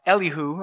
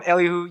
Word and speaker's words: Elihu: The second one will be kind of Mr. Fifth Wheel, Elihu, or if from the southern states, we can call him Elihu. I've heard that, but I'm just going to call him Elihu. You just pronounce it any way you want.